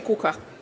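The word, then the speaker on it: kaka: Le gâteau en suédois, c'est coca.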